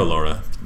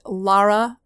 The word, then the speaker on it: Lara: Lara Laura. – Lara Laura Laura. – It's a little ambiguous. So all the way you can always remember it is just Lara rhymes with star.